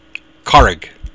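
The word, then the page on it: carraig: Irish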